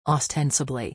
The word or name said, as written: ostensibly